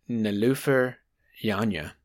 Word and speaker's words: Niloufer Yanya: Earlier this year, he brought up this album that came out earlier this year called Painless by an artist named Niloufer Yanya. That's how you pronounce that.